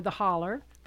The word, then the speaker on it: holler: But Elaine, not too long ago, finished a really amazing multimedia presentation called The Hollow, or The Holler, but it is spelled like hollow.